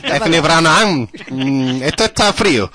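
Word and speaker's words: Eithne Ní Bhraonáin: se escribe como así, algo así como Eithne Ní Breathnán, en gaélico se pronuncia Eithne Ní Breathnán.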